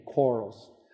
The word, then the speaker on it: Quarles: Although, how would you say it in the Deep South? Quarles? Quarles. Quarles. So, okay, so you soften and lengthen the A, Quarles.